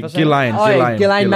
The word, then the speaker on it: Ghislaine: Ghislaine Maxwell.